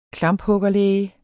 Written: klamphuggerlæge

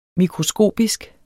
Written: mikroskopisk

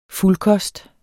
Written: fuldkost

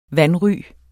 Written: vanry

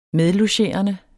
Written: medlogerende

